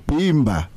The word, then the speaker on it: Bimba: Our word for today actually rhymes with Simba. Mm-hmm. It's Bimba.